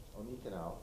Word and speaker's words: Onekanew: So welcome, Onekanew.